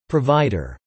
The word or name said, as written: provider